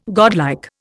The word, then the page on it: godlike: Indian